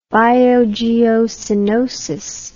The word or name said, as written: biogeocenotic